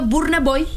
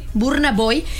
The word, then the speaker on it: Burna Boy: se pronuncia así, güerito, Burna Boy. Tal cual, sí, Burna Boy.